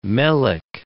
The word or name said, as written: melic